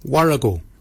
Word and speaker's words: worragol: Barkin is spelled B-A-G-I-N-Y. Barkin. Barkin is your beard. The upper mouth, we say warrigal. Warrigal. Warrigal is spelled W-O-R-R-A-G-O-L, Warrigal. Warrigal is your upper mouth.